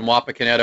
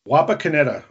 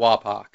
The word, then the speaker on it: Wapakoneta: Can you pronounce that again? What? – Wapakoneta. – Wapakoneta. Okay. – Wapakoneta. And people call it Wapak or Wapak. Okay. So that's kind of the short.